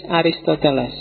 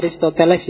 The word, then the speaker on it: Aristoteles: Oke, terus muridnya yang ketiga, cucu muridnya Socrates, Aristoteles. Bacanya Aristoteles ya, bukan Aristoteles. Ya, sok-sok diwaca Aristoteles. Kalau dalam bahasa Arab Aristo saja sebenarnya. Atau ada yang nulisnya Aristatalis. Yes, monggo lah, sementing maksudnya orang itu.